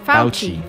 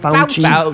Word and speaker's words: Fauci: Is that how you say his name? Fauci? – Yeah. – Fauci. – Fauci. – Fauci. Like if you're saying, ouchie, come help me, Dr. Fauci.